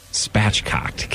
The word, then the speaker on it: spatchcocked: Rosemary, garlic, spachoked. Spachoked. Is that how you say it? – Spatchcocked.